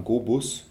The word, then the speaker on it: GoBus: Begonnen hat das Ganze blau und mit dem Namen GoBus. – Oh, okay. – Das klang irgendwie auch in Englisch, wie du es schon richtig ausgesprochen hast, ganz snappy.